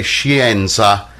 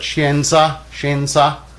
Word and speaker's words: scienza: Oftentimes people learning will say scienza as though the I and E are both pronounced, but scienza for science.